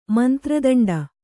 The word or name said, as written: ಮಂತ್ರದಂಡ